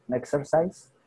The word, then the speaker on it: Nexersys: Entonces, la primera de ellas se llama el Nexer, Nexersys. No sé si se pronuncia así. ¿Es el Nexersys o Nexersys? – Nexercise. – Nexercise. Suena más como un exercise, ¿no? – Como pues, eh, ejercicio, en inglés, sí.